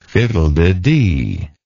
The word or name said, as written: fiddlededee